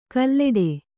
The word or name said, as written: ಕಲ್ಲೆಡೆ